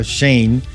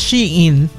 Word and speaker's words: Shein: Esta le va a gustar su compañía favorita, Shane. ¿Cómo que se pronuncia? Shane, es verdad, ¿no? – Yo Shane. – Siempre he dicho Shane. – Es Shein. – Shein, ¿verdad? Shein. Eso es lo correcto.